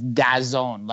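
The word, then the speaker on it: DAZN: Yes, no, that is what they wanted pronounced as Dazone, like 1996 in the house, Dazone.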